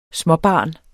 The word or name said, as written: småbarn